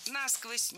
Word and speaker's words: насквозь: Если бы лед падал на дно, то у нас бы промерзали бы все водоемы, собственно, зимой насквозь, и весной бы размораживалось уже все стерильное. – Насквозь неверно. Ударение в этом наречии должно падать на второй слог. Насквозь. Так зафиксировано в словарях. Только насквозь.